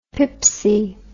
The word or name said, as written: pipsy